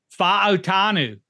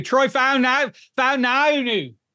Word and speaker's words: Fautanu: Go ahead, you say it. Say it like a cheesehead. – Fautanu. – Troy Fautanu.